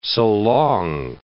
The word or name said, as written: so long